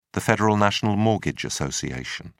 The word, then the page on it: federal national mortgage association: British English